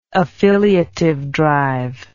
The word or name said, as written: affiliative drive